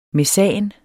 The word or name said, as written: Mesanen